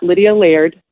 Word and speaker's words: Lydia Laird: If you guys want to check me out, you know, all social media accounts is just at Lydia Laird. And that's how you say my name for anyone who's wondering. Don't say Lard. I will never be a friend again. It's Lydia Laird.